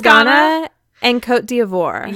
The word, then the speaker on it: Côte d'Ivoire: It's Côte d'Ivoire.